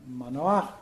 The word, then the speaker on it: Manoah: Direi che in questo racconto il padre, il futuro padre di Sansone, Manoah, no? Pronuncio come si pronuncia nel testo ebraico, fa un po' brutta figura, perché l'angelo appare a sua moglie, non a lui.